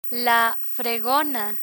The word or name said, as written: fregona